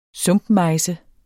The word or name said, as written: sumpmejse